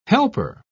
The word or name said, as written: helper